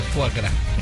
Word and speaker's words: foie gras: Que a pronúncia certa é foie gras.